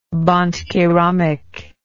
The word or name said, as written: bandkeramik